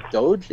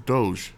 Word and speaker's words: Doge: No, the E makes the G soft. Doge.